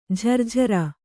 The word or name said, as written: ಝರ್ಝರ